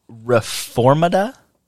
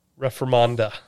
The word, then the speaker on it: reformanda: Semper reformanda? – Reformanda.